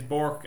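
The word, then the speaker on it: Bourk: Like, yeah, listen, sure, my second name is Bork, and it's B-O-U-R-K, but every time I say, like, in Ireland, some people say Burke, and they spell it B-U, so it's the same, me, like, with my name, so.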